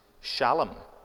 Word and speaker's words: Shalom: It's actually pronounced Shalom in Hebrew. I don't actually know why we call it Salem, because it's the SH sound in Hebrew.